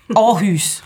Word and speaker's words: Aarhus: Habe ich das richtig gesagt? Yes. Aarhus. Aarhus.